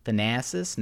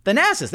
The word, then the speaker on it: Thanasis: Thanasis? No, that's not it. Thanasis, there we go. I got it right on the first try.